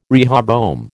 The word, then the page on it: rehoboam: American English